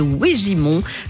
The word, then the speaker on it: Wégimont: Donc, allez tapoter, domaine provincial de Wégimont, comme ça se prononce, M-O-N-T à la fin.